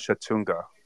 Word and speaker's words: Chattanooga: The school is called Chattanooga. That's how they pronounce it. – You mean Chattanooga? – Oh, is that how you say it? – Yes.